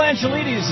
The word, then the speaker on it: Angelides: Phil Angelides is with me.